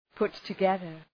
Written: put together

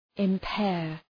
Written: impair

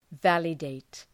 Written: validate

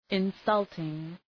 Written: insulting

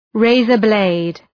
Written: razor-blade

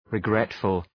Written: regretful

